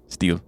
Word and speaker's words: stele: The Katamua Stele? – Steel. – Steel? – Yeah. – Why steel. Is it spelled like that? – Because it's fancy. – S-T-E-L-E? – Yeah. Stele. – steel.